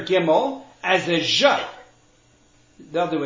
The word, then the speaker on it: Gimel: Another difference, the letters Gimel and Ayin among Ashkenazim will be pronounced like I just did. Gimel is G, Ayin is A. Whereas in Yemenites and some Sephardim, they pronounce them as guttural sounds out of the throat. So it's ga. And the ayin is ga.